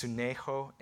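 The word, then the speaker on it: sunecho (συνέχω): And the Greek word here is pronounced sunecho,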